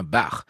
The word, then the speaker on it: Bach: Alors en matière de prononciation des noms de compositeurs, vous avez sans doute remarqué que je fais très attention à bien dire Max Bruch, comme on prononce Ernst Bloch et comme on devrait prononcer Jean-Sébastien Bach. Quoiqu'en France, on ait plutôt l'habitude de dire Jean-Sébastien Bach.